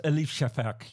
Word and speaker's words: Elif Shafak: Am I saying her name right? – Yes. I mean, well, it's always Elif Shafak.